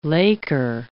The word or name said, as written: laker